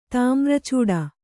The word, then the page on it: ತಾಮ್ರಚೂಡ: tāmra cūḍa